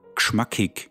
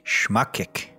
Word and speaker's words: gschmackig: In Austria, we say geschmackig. – Geschmackig. – Geschmackig, with the G in front, like geschmackig. – How do you spell that? – Good question. G-S-C-H-M-A-C-K-I-G.